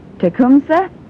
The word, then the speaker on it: Tecumseh: Georgia Saw Shop has a complete line of parts for Briggs & Stratton, Tecumseh. Does that sound familiar to you? Did I say that right? Oh, he's nodding, yay.